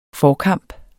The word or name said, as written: forkamp